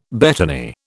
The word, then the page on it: betony: British English